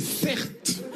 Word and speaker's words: Certes: Mon prénom c'est Certes.